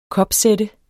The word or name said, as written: kopsætte